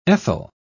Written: ethyl

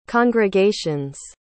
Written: congregations

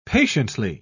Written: patiently

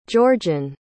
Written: georgiann

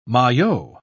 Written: maillot

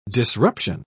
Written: disruption

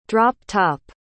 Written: drop-top

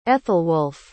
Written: ethelwulf

